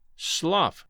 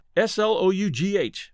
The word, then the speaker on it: slough: One thing I wanted to note slough, S L O U G H, when referring to a swamp, is pronounced like someone saying ow or slough. Slough rhymes with ow in this particular instance.